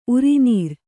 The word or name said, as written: ಉರಿನೀರ್